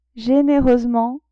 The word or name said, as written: genereusement